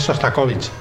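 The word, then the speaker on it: Shostakovich: Sostrakovich. – Pero no es Sostrakovich, es Sostakovich.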